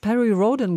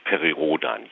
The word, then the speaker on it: Perry Rhodan: Ich habe jetzt Perry Rhodan gesagt, englisch klingend. Man soll ihn aber irgendwie deutsch aussprechen. Tun Sie das auch? – Intern sagen wir tatsächlich Perry Rhodan, ja. Was meist daran liegt, dass ich wie die meisten meiner Leser angefangen hat zu lesen, als wir noch gar kein Englisch sprachen, also mit 8, 9 oder 10 Jahren. Und da sind wir gar nicht auf die Idee verfallen, man müsste das Englisch aussprechen.